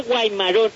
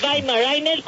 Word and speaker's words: Weimaraner: Hay gente que le dice Weimarón, y se oye tan feo, es Weimaraner con W porque es un perro alemán, y se pronuncia con B como Volkswagen, Weimaraner.